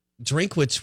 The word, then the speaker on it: Drinkwitz: Drinkwitz. – Yeah, Eli Drinkwitz. – Drinkwitz from App State. – Yep. – You know, Drinkwitz was,